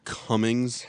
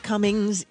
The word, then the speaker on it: Cumming: It's spelled C-U-M-M. – Alan. – And his last, it's not Cummings. – Cummings. – It's Cumming. His name is Cumming.